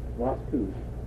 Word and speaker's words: Lascaux: How do you say it? Los Angeles? Lacouche, France? Lascaux, France. And they had all kinds of cave dwellings.